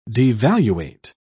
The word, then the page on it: devaluate: British English